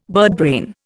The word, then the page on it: birdbrain: Indian